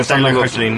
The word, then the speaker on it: Hoechlin: Están felices con Tyler Hoechlin, ¿no? – Exactamente, le están dando.